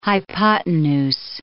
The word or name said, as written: hypotenuse